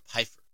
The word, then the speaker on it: Pfeiffer: Is it Pfeiffer or Pfeiffer? – Pfeiffer. – Pfeiffer. – The P is silent, except when I give it to her.